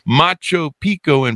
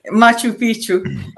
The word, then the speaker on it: Machu Picchu: She saw sites such as the ruins of Machu Picchu in Peru, the Mayans in Mexico. I mispronounced that, didn't I? That's why you're laughing. – Machu Picchu.